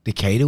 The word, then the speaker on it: Decatur: Decatur, Illinois. D. Peoria, Illinois. Did I say that right? – Peoria. Nobody goes there. That's cool. – Peoria. – Decatur. – Decatur was good.